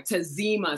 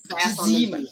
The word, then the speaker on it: Tazima: Tazima. So staff on the Z. That's how to remember it. Tazima.